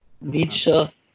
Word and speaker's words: Nietzsche: Nietzsche.